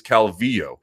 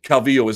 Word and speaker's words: Calvillo: There are a number of different ways you can pronounce the double L in Spanish. If you go to Argentina, they say it like a sh. They pronounce it Medellin. Bet you didn't know that. You want to go to Buenos Aires and correct them? It is not true. It is not true that the only pronunciation for that is Calvillo. Calvillo is not incorrect.